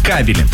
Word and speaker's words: кабели: Но правильно, если мы говорим про электрические сети, кабели.